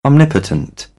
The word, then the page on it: omnipotent: British English; ɒ